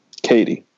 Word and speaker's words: Katy: Guys, is it Katy or Caddy? – Katy.